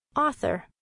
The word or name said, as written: author